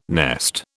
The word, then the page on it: nest: British English